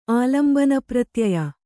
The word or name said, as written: ಆಲಂಬನಪ್ರತ್ಯಯ